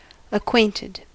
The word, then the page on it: acquainted: American English